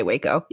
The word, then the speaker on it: Waco: I think that's how you say it. It's W-A-K-O. – And yeah, I would say Waco.